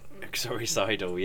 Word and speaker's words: uxoricidal: Uxoricidal. – Uxoricidal, yes.